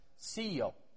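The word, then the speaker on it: seio (σείω): Now, the Greek word translated moved here is a word that's pronounced seio. And it is a word from which we get the word seismic from.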